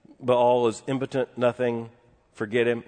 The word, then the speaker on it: Baal: There, and the proper name of this, if you grew up in a Southern Baptist Sunday school like I did, you heard this idol mispronounced as Baal. Don't go following Baal. It's a diphthong, dear friends. It is Baal.